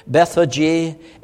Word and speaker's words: Bethphage: Now when they drew near Jerusalem to Beth, and I'll just try to pronounce it English pronunciation, Bethphage and Bethany at the Mount of Olives,